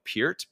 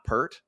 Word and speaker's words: Peart: I say his name is Neil Peart because that's how you actually pronounce his name. – That's how you pronounce his name. – It's not Pert.